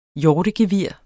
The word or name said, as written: HJORTEGEVIR